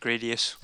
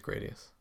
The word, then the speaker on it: Gradius: Gradius. I don't know how you pronounce that. – I would have said Gradius. – I think it's Gradius. – I think so, yeah. – It's officially Gradius now.